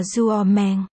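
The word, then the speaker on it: duo meng (做梦): Nằm mơ trong tiếng Hán là duo meng. Đọc là duo meng. Trong đó chữ meng, meng. Đồng âm với chữ tên meng, meng trong tên của Mạnh Bình.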